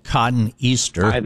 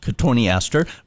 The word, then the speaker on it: cotoneaster: Yep, it's likely cotoneaster, kind of spelled cotton easter. – But pronounced cotoneaster.